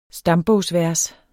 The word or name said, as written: stambogsvers